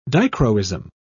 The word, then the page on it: dichroism: British English